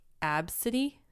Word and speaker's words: Abcde: People are naming their kids, it's pronounced like Absidy, but it's spelled A-B-C-D-E.